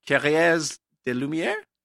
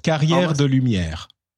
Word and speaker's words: Carrières de Lumières: But in Provence, I went to this thing called, I'll pronounce it wrongly, and then you can correct me, Carrière de Lumière? – Almost. Carrière de Lumière.